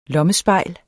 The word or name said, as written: lommespejl